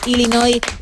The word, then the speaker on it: Illinois: ese nombre fonéticamente es Illinois. Así que Illinois y la S. Así que, bravo, sin pronunciar la S final, Illinois, una vez más, tu apunte de geografía.